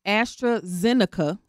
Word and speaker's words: AstraZeneca: AstraZeneca, say that right, Nelson? – That's correct, yes.